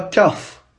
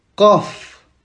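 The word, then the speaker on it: kaf (ق): Et pas kaf, on dit kaf. Ça doit venir de la gorge.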